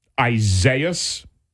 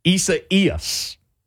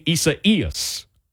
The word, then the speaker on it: Isaias: Here's how to say that name. Isaias. Isaias. The accent goes on the second E, which is the I, and you say Hurricane Isaias.